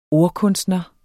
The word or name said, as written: ordkunstner